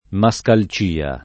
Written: mascalcia

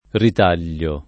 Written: ritagliare

